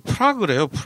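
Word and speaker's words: Prague: 프라하가 영어로 그 프라그래요. 프라그? 프라그라고 음. 한다는데. 그 무슨 안티 프라그 무슨 칫솔 치약처럼 뭐 네. 그렇게 프라그 뭐 이렇게 발음한다 그러더라고요.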